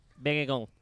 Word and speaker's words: Baekkegard: Baekkegard.